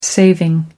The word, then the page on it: saving: American English